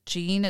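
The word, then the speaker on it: Jeanne: Now, her name is spelled like Jean. It's J-E-A-N-N-E, but it's pronounced Jean.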